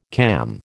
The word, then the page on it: cam: American English